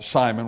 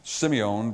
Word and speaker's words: Simeon: The first half of chapter 2 is much better known than the second half, which involves Simon, which is actually pronounced Simeon, but we'll just call it Simon so you know who we're talking about.